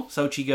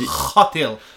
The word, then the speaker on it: Xochitl: Yeah, Sochi, like the Winter Olympics. – Yes. – That's how I remember it. – Xochitl, like you're, you just had a habanero.